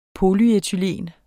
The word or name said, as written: polyethen